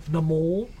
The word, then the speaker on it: Namor: Namor, I'm pretty sure. – Namor? – MCU showed me how to say it right last year. Namor.